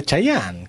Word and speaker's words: Chayanne: Cheyenne, ¿cómo lo pronuncia? Chayanne. ¿Cómo Cheyenne? Sí. Chayanne.